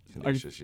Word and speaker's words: Canisius: Yeah, for this upcoming doubleheader against Kansas, or how do you say it? No offense, but who? Who are they? – Is that how you say it? – I don't even know. – I think it's Canisius. – Yeah, it could be like that.